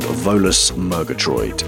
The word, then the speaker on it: Volus Murgatroyd: It's called Volus Murgatroyd. – I'm glad you can pronounce that. You pronounced it perfectly.